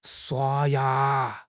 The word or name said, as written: shua1 ya2